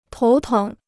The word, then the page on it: 头疼: tóu téng